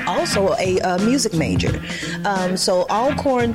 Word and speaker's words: Alcorn: You went to Alcorn State. What did you learn there? – I thank you for pronouncing that correct.